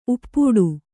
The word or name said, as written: ಉಪ್ಪಟ್ಟ